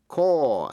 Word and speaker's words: corps: Corps, C-O-R-P-S, corps là một sư đoàn. The medical corps là sư đoàn quân y. Số nhiều, đọc là corps. The Marine Corps, sư đoàn thủy quân lục chiến. Phân biệt với chữ corpse, C-O-R-P-S-E là xác chết. Vậy corps và corpse. Psychology, tâm lý. Psalm là thánh ca, P-S-A-L-M. Receipt, giấy biên lai, R-E-C-E-I-P-T. Coup, C-O-U-P, là một cuộc đảo chính.